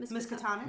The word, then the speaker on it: Miskatonic: Miskatonic? – Yeah.